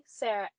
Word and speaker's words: Sara: But you also have another, on other social channels, you spell it C-O-F-F-E-Y. So exactly. Where can people reach you? – Okay. So my LinkedIn is Rebecca Sarah Coffey. I'll spell that R-E-B-E-C-C-A Sarah S-A-R-A Coffey C-O-F-F-Y.